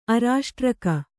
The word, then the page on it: ಅರಾಷ್ಟ್ರಕ: arāṣtraka